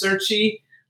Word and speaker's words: Cerchi: Our first guest is going to be Max Cerchi.